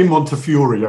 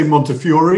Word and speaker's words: Montefiore: We say Montefiore. I know in America, some people say Montefiore. We say Montefiore. Okay. And of course, it's originally an Italian name.